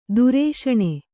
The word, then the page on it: ದುರೇಷಣೆ: durēṣaṇe